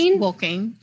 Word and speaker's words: Wolking: Is it Wolking? – Just Wolking, yeah.